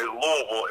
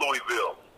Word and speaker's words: Louisville: He taught me that the correct pronunciation is Louisville. It's not Louisville.